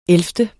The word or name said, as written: ellevte